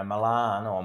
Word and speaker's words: Milano: Yeah, well, I'm sure, you know, most people know that the, you know, Milan or Milano, as the locals say, it's world renowned as the fashion centre of Italy fashion and world fashion probably.